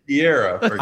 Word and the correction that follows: Pereira: Pereira. – Pereira. Pereira. Pereira. Uh. Pereira. Think about Pereira. Th- think about pa, and then the way we like steak, rare.